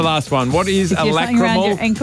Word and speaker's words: lacrimal: Lacrimical carungles. – That is not how you say it, I'm sure. – Caruncle. – What? – A lacrimal caruncle.